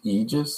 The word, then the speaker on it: Aegis: Yeah, and it's pronounced Aegis, so the A is silent, and I was trying to use the logo in a way to make you see it. – Pronounce the E more. Forget about the A.